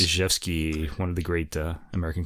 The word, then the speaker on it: Rzewski: So Paul Jacobs, Winsboro Cotton Mill Blues, composed by Frederick Zhevsky. Check it out. Zhevsky is spelled R-Z-E-W-S-K-I. Okay, so it starts with an R, not a Z.